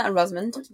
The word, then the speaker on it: Rosamund: Yeah, Rosamund.